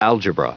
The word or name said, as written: algebra